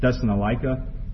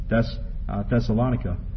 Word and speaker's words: Thessalonica: You know, I've always pronounced it Thessalonica, but I've learned actually today, I checked many sources, even Schofield's back pronunciation of proper names, and it's actually pronounced Thessalonica.